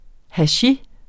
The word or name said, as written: hachis